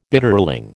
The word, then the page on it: Bitterling: American English